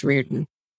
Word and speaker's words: Riordan: It's the Irish spelling, but it's pronounced Riordan.